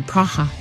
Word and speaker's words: Praha: Yeah, or Praha, as they pronounce it.